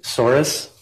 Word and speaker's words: Soares: Steve Soares, S-T-E-V-E, S-O-A-R-E-S.